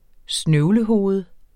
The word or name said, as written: snøvlehoved